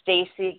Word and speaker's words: Stacey: H-U-B, and then another forward slash, and then my name, which is Stacey Golden. Stacey is spelled S-T-A-C-E-Y. Golden is G-O-L-D-E-N.